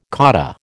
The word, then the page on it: cotta: American English